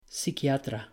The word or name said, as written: pisquiatra